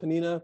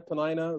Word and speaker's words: Peninnah: When the time was that Elkanah offered, he gave to, I don't know how you pronounce it, Peninnah, Peninnah. It's like one of those names. You get like two or three different ways depending where you are.